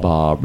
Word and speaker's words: bas: No, it's pronounced Bas. B-A-S is pronounced Bob.